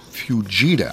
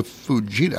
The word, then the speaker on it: Fujita: The scale for estimating tornado strength is the Fujita. – I've not pronounced that correct. That's the Fujita.